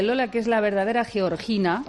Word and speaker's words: Georgina: Lo ha pronunciado como debe, Georgina. – Georgina, porque ella es de Jaca.